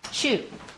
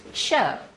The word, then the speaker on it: shew: There's one word when we were in our little class, and it was spelled S-H-E-W. And so we got to that, and we said, shoe. The tables of shoe bread. And our teacher said, well, actually, that is pronounced show. It's not a really big shoe. It's S-H-E-W.